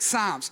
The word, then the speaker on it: Psalms: spelt with a silent P at the start. So it's spelled Psalms, but it said Psalms.